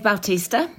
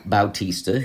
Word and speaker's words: Bautista: But this is Dave Bautista. – Bautista, who was also in Guardians of the Galaxy and a few other films.